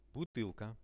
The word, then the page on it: butylka: Russian